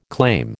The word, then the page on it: claim: American English